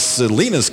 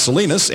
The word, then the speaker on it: Salinas: who writes to inform us that the city of Salinas, California is pronounced Salinas and not Salinas, as I have been saying.